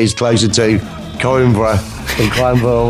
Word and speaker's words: Coimbra: Coimbra.